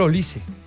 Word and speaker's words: Olise: Sí, sale Michael Olise. Y fue a una entrevista y dijo, venga, explíquenos cómo se pronuncia su apellido. Mi apellido es de origen nigeriano, etcétera, tal. La correcta pronunciación es Olise.